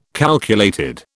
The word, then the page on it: calculated: British English